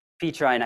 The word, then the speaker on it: Petrine: And so we're looking at what we're going to be calling the Pauline vision and the Petrine, I think is how it's pronounced, a Petrine vision of these two apostles.